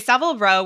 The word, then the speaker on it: Savile Row: He he called out Savile Row. Am I saying it right? – Yeah. – I've always read Savile. – Okay, Savile Row, which is a street in London that's known for bespoke men's tailoring.